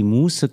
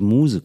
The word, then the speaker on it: Muse: Und zwar habe ich dann immer gesagt, wenn mich die Muße küsst. Und es heißt es aber, wenn mich die Muse küsst.